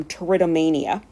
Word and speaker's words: Pteridomania: I decided to open with this passage not only because Kingsley is credited with coining the term Pteridomania, which begins with a silent P,